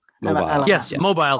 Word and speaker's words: Mobile: Mobile. That's how you say it.